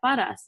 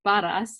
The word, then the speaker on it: Perez: And I'm like, oh, like I pronounce it like Natalia Paraz, and like that's how it's pronounced. And he's like, no, it's not. And I'm like, excuse me? And he said it's pronounced like Perez, and I'm like, no, it's pronounced Paraz.